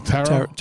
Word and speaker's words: Tarot: Tarot? – Tarot. Right? The second T is silent.